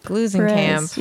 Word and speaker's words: Glusenkamp: Did I say that right? – Glusenkamp? – Glusenkamp. Yes, I think that's correct.